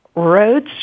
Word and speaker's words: Rhodes: So today we have Hannah Christenson, Allison, Allison, is it Rhodes, or how do I pronounce that properly? – Rhodes is correct okay, and Therese Goren.